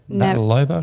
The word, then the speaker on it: Navratilova: Navratilova.